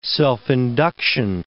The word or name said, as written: self-induction